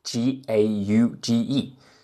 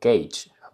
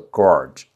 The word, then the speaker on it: gauge: 什么叫 gauge 呢？这儿做一个订正，有朋友在评论区已经说了，这个单词 g a u g e 量规应该发音为 gauge，不是我这个 gauge，这是错了，所以我特别在这儿。